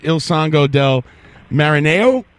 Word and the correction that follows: Il Sogno del Marinaio: Il Sogno del Marinaio. – Ah, Il Sogno del Marinaio. – Il Sogno. Yeah, repeat after me.